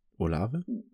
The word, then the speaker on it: Olave: Olave habt ihr ihn letztes, letzte Woche genannt. Wird er so ausgesprochen? Olave? – Hat jeder so gesagt.